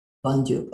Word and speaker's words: Dondyuk: Dondyuk, okay.